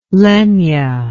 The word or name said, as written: Lenya